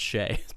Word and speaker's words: Chelly: It's spelled Chelly, Chelly Tours, but it's pronounced Shay.